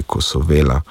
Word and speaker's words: Kosovela: Sam osebno sem tudi dejansko zagovornik, da naj bo naglas na tistem mestu, kjer se tudi izgovarja v določenem družinskem deblu oziroma pokrajini. Tako da bi vsekakor moral biti Kosovela, to bi morala biti prva varianta.